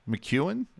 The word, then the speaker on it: MacEwen: I'm going to start this with Vancouver Canucks forward Zack MacEwen. Am I pronouncing that correctly? Yes.